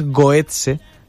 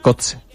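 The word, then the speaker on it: Götze: ¿Por qué dices Goetze, que es como se diría en alemán, si tú tienes que decir Kotze, castellanizándolo?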